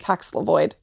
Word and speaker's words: Paxlovid: Oh, wait, that sounds right though, because it sounds like COVID. – That's what I think I say it that Paxlovid.